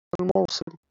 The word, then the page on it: الموصل: [alˈmawsˤil]